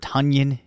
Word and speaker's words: Tonyan: Let's talk about Robert Tonyan Jr. And apparently you do pronounce it that way, much like Funyuns, which yes.